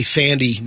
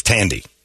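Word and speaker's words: Thandie: And I'd always say Thandi Newton. – It's Tandy. – It's not Thandi Newton.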